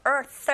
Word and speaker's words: earth: 맨 끝에 단어 earth 하실 때 th 발음 th 잡아주시면 되고요.